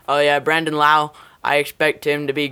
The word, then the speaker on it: Lowe: I've been following him since college because My uncle's name is Brandon Lowe, spelled the same way, but he pronounces it Lau.